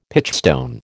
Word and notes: pitchstone: American English

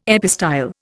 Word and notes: epistyle: Indian

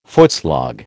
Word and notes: footslog: American English

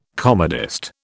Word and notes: comedist: British English

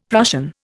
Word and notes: Prussian: Indian

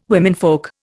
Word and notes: womenfolk: Indian

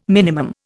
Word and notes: Min: Indian

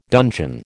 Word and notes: dungeon: American English

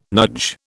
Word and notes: nudge: British English

